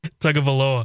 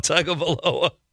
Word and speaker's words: Tagovailoa: Tagovailoa. – I don't think Chad said it right on the Tagovailoa. – Tagovailoa.